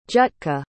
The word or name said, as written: jutka